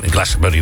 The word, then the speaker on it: Glastonbury: Dat vindt altijd in Glastonbury natuurlijk plaats.